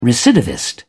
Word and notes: recidivist: British English

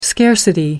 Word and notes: scarcity: American English

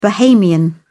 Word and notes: bahamian: British English

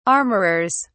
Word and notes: armorers: British English

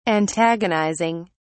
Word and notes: antagonizing: British English

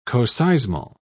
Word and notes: coseismal: British English

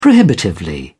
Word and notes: prohibitively: British English

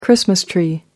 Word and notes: christmas tree: American English

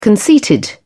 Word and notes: conceited: British English

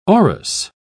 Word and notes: aurous: British English

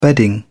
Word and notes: bedding: British English

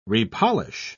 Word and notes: repolish: British English

